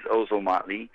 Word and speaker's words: Ozomatli: You pronounced that perfectly, actually. It is Ozomatli.